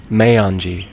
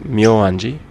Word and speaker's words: Meianji: This temple is called Meianji, or other people pronounce it Myoanji.